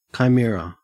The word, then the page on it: chimera: Australian English